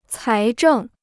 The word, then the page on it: 财政: cái zhèng